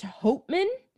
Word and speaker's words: Hauptmann: And so because they had this license plate, they tracked it back to this man named Richard, I think it's pronounced Hauptmann. Mm-hmm. It's spelled Hauptmann. – Yeah, probably it's like Hauptmann.